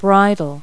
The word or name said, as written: bridle